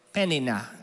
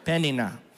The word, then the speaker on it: Peninnah: Hannah, and there's a lot of ways to pronounce this, but we're going to go with Peninnah. Everyone? Peninnah.